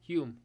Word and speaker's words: Hume: Hume, yes. – H-U-M-E? – I'm saying that right. – Yes, yeah. That's correct.